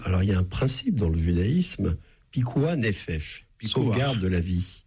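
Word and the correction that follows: pikuach nefesh: Pikuah nefesh. – Alors, je prononce évidemment très mal, mais vous vous prononcez beaucoup mieux.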